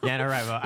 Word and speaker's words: NaNoWriMo: NaNoWriMo. I just like totally butchered that. No, you're totally good.